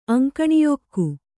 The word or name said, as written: ಅಂಕಣಿಯೊಕ್ಕು